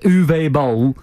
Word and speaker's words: Boll: But I think Boll, is it Boll? Or balls. But I think it's just bowl, isn't it? – Well, he's balls, isn't he? – That's well pronounced. Uwe Boll.